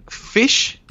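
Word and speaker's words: Phish: Pish? Pish, que yo nunca he podido pronunciarlo bien. Fish. Sí, Fish. ¿Con PH? Sí, el de PH, sí, Fish.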